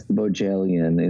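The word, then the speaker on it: Bohjalian: Chris Bojalian is the author of The Flight Attendant, which is a hit series on HBO Max.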